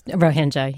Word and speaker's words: Rohingya: And the Buddhists, the powerful Buddhists, the more powerful ones, are saying to the Rohingya, is that how you say it? – That's Rohingya, yeah. – Rohingya Muslims, don't belong there, should be gone, shouldn't be killing animals and assault their senses and sensibilities.